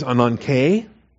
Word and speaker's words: ananke: The noun form is ananke, A-N-A-N-K-E. The long E is the eta there, ananke.